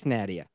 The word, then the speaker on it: Nadia: There's new merchandise, Nadia.